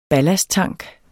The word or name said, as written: ballasttank